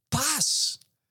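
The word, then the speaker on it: pas (πᾶς): It's pronounced poorly by me, pas.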